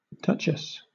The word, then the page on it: duchess: British English